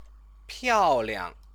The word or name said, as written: piaoliang